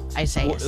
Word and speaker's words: Isaias: Isaias. Well, no, but it's probably the Spanish rendering of it, which if that's the case, then the I is pronounced like an E.